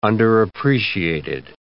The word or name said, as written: underappreciated